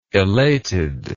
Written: elated

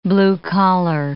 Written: blue-collar

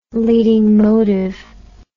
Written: leading motive

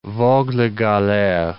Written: vogue la galere

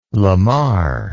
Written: lamar

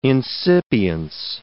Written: incipience

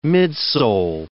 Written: midsole

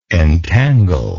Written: entanglingly

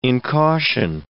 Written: incaution

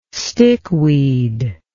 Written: stickweed

